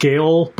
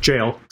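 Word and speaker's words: gaol: I always thought it was pronounced Gale, but apparently I looked it up and the correct pronunciation is just jail.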